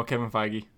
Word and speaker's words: Feige: No, Kevin Feige.